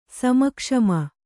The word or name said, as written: ಸಮಕ್ಷಮ